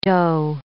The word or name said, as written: doe